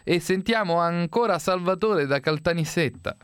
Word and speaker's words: Caltanissetta: Perché, chissà perché, intanto complimenti perché lei ha detto bene, ha detto Caltanissetta e non Caltanisetta come dicono i nostri amici del nord.